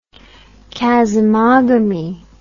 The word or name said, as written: chasmogamy